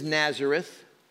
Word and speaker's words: Nazaret: Thirdly, the place is Nazareth. Nazaret is how you say it in Hebrew.